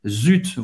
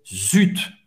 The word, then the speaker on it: zut: Zut, vous vai pronunciar o T. Z-U-T en français, zut. Para fazer esse U, faz a boca do U e fala I. U, zut, j'ai raté le train.